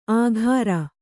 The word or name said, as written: ಆಘಾರ